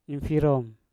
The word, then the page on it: infirom: Mpur